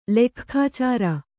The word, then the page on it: ಲೆಕ್ಖಾಚಾರ: kannada